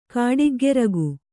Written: ಕಾಡಿಗ್ಗರಗೆ

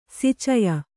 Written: ಸಿಚಯ

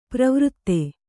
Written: ಪ್ರವೃತ್ತೆ

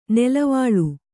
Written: ನುಲುವಾಲೆ

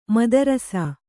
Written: ಮುದ್ರಸ

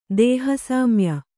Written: ದೇಹಸಾಮ್ಯ

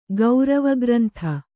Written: ಗೌರವಗ್ರಂಥ

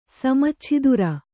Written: ಸಮಚ್ಛಿದುರ

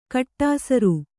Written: ಕಡುಸಾರೆ

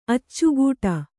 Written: ಅಚ್ಚಿಕೊಡು

